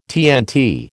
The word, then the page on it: TNT: American English